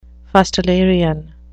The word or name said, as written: fustilarian